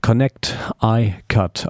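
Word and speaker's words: Connect_icut: Connect iCut, also Connecticut eigentlich ausgesprochen, aber Connect iCut geschrieben,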